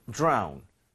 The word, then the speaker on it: drone: 이것도 발음할 때 드론보다는 DR이 충돌을 하면서 지읒 비슷하게 소리가 나게 됩니다. So, drown.